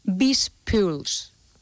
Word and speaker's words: BizPulse: Han creado una plataforma que se llama BizPuls. Bueno, se escribe BizPulse, pero me imagino que se debe decir así.